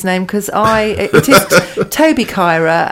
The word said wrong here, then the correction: Carr: and I mispronounced it. But Toby Carr is very, very exciting young whippersnapper chef on the way up.